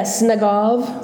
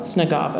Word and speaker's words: Snagov: Yeah, Snagov. – Snagov, okay.